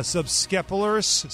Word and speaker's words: subscapularis: In his first outing following a no-hitter last week, an MRI on the shoulder revealed a subscapularis. – What? – Something like that. Strain. I said it right the first time.